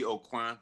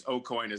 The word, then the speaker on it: AuCoin: AuCoin. It's it should be AuCoin. That's the French pronunciation, but we don't speak French. – Yeah. – So it's AuCoin as if it were Irish. O apostrophe coin.